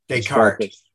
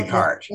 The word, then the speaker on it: Descartes: Descartes. You don't pronounce the S at the end. – You don't? – Descartes.